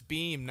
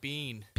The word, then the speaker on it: Beam: I just learned it's Beam, not Bean. – Beam. Beam with an M.